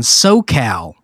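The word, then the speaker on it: Soquel: And like, I did a gig at this family restaurant in SoCal, which is spelled SoCal. – Okay. – Oh, yeah. – And it's very close to... – With a Q? – Yes. I think it's S-O-Q-U-E-L.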